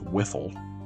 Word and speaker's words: WFL: The history of the WFL, pejoratively pronounced Wiffle, is insane.